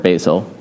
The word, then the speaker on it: Basil: Gregory of Nazianzus, Basil, it looks like Basil, the word Basil, usually it's pronounced Basil, Basil of Caesarea, and his brother Gregory of Nyssa.